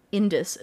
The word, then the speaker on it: Indus: They were used largely amongst the Indus, is I believe how you say it, Indus Valley civilization.